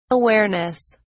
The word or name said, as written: awareness